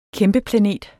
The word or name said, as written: kæmpeplanet